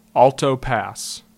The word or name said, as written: Alto Pass